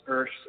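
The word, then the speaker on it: Örs: My father's name is Ursh. Well, my family's Hungarian, and it's spelled O-R-S, and so I kind of snuck it in there in a little way.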